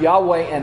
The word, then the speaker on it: Yahweh: It's basically four letters, YHWH, and it is commonly pronounced Yahweh in our time.